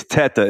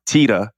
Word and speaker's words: Teta: yeah, this is gonna be hilarious, Rob, because it's it's obviously I'm Italian, so it's it's teta is the is the right pronunciation, but we in my family pronounce it tita.